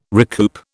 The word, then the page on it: recoup: British English